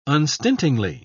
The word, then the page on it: unstintingly: British English